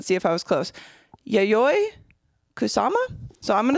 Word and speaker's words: Yayoi Kusama: Yayoi Kusama.